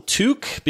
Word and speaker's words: Tooke: Tuck, I'm going to call him Tuck, even though everybody calls him Took, because it's spelled T-O-O-K-E. But Robin Hood is Friar Tuck, so I think people are saying his name wrong.